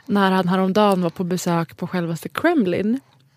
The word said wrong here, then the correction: Kremlin: Nu uttalade jag det som Kremlins. – Ja. – På självaste Kremlin.